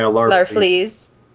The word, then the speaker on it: Larfleeze: Larfleeze or whatever? – Yeah, Larfleeze.